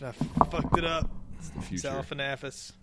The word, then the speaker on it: Galifianakis: Zalifianakis and Clooney. – Zalifianakis. – How do you say his name? – Zach Galifianakis. – Zalifianakis.